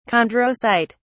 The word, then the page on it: chondrocyte: British English